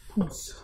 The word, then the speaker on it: Pousse: Yeah, it's spelled P-O-U-S-S-E dash cafe. – Poose. – Like moose. – Yeah, I guess like moose, yeah, but with a P, I don't know.